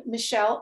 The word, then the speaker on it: Michelle: Yes, my website is my name, Michelle, M-I-C-H with two L's, E-L-L-E, my middle initial M.